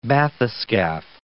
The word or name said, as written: bathyscaph